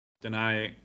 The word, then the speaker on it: Danai: I can't, I'm probably not pronouncing her name correctly. Is it Danai Gurira? – Danai.